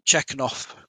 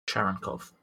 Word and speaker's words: Cherenkov: And I'm going to screw up this pronunciation, but you also detect Chekhov radiation. – Cherenkov.